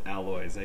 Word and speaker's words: Alois: And if I may, the last name is pronounced Alois. I do pronounce the S.